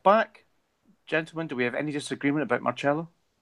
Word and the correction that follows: Marcelo: Only the, only how you pronounce his name. Sorry, Marcelo. you're Brazilian. Marcelo.